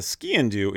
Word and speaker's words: sgian dubh: For those who do not know, skin-do It's spelled, it's a Gaelic word, S-G-I-A-N mm-hmm. space D-U-B-H. Mm-hmm. Pronounced skiing, do like skiing into something, skiing.